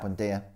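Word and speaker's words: Buendía: Buendia. – Right, yeah. Buendia.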